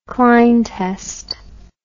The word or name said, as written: kline test